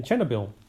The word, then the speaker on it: Chernobyl: Chernobyl, and that's exactly how you pronounce it in the Ukrainian language.